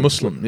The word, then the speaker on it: Muslim: People what? say Muslim, and actually that means something different in what, Arabic, but difference? it's Muslim. Yeah, what's so the difference? Muslim actually means like a dirty person, like a z, like the z sound is different.